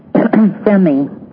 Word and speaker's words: Fehmi: Femi. It's spelled F-E-H-M-I, Les Femi.